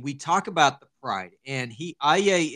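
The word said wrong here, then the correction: Aulë: is the, am I saying that right? – I think it's Aule. – Aule. – No, you got me saying it wrong. – This is Aule.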